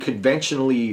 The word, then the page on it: conventionally: American English